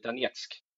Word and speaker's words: Donetsk: Men en ganska intressant aspekt som jag har är att jag har, jag har ju kontakt med en kille i Donetsk. – Mm. – Nu sa jag det med det ryska uttalet.